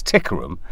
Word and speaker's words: Tikaram: But actually, it wasn't very phonetically kind of friendly, so people still pronounced it completely wrong. It's Tikaram.